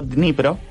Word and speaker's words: Dnipro: Y una vez vi que alguien usaba para jugar al Dnipro, D-N-I-Pro, Dnipro. Yo porque no voy a poder pronunciar la D todas las otras veces, pero usted imagínense que está. Dnipro.